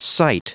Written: site

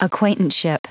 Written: acquaintanceship

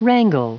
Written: wrangle

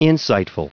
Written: insightful

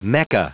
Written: mecca

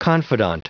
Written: confidant

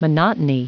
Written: monotony

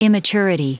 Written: immaturity

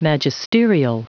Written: magisterial

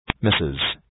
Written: misses

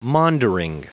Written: maundering

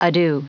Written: ado

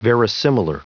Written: verisimilar

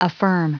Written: affirm

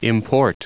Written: import

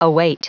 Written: await

